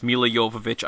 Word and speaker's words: Milla Jovovich: Uh, that's Milla Jovovich. I know that's the right pronunciation, everybody, because uh, she does have commentary on the Blu-ray and I hmm.